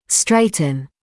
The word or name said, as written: straighten